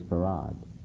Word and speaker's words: Fard: So the spelling, the pronunciation of F-A-R-D is Farad, though it's not spelled that way.